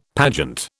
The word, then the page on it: pageant: British English